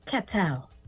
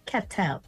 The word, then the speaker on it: Ketel: It's pronounced Ketel. – Ketel. – It's pronounced with a K. Yeah, it's French. It's pronounced with a K. Ketel.